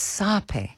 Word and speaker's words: sape: I actually just Googled the pronunciation. It's sape.